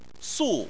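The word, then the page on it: su: tone 4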